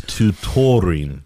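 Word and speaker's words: tourtière: It's tutorine. – Tutorine. – Is that the planet from Star Wars? – Yes. Yes. – The same one? – Yes. Good comparison.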